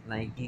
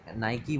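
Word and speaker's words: Nike: नाइक के नाइकी के स्पेलिंग होते हैं एन आई के ई उसको हम नाइकी बोलते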